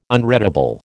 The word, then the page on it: unreadable: American English